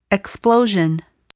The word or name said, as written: explosion